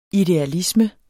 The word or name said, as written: idealisme